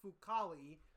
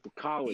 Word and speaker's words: Fucale: Talk about our young man, Zach Fucale. That's how you say his name properly. – Fucale.